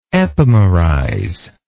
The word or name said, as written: epimerize